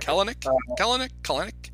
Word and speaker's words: Kelenic: Despite Jared Kelenic finally breaking out. – Yeah. Hi, Kelenic. – Kelenic. The middle E is silent.